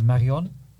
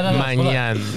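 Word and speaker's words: Maignan: But when you go through their team, they got like, but their keeper, I can't pronounce it, but I think it's Marion. – I can't pronounce it. – No. Magnan.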